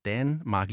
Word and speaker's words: Danmark: et smalt A først, Danmark, ligesom i kat, og, øh, øh og så er det med det brede, eller det høje A, øh, Danmark, altså ligesom øh, far.